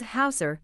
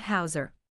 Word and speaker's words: Howser: It was Howser, not Hauser.